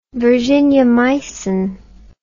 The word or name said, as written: virginiamycin